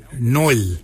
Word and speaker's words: nyooly: For your elbow, we say noil. Noil is spelled N Y O O L Y.